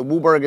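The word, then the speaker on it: WUBRG: So Wooburg is how we pronounce.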